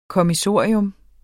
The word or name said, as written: kommissorium